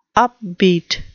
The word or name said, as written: upbeat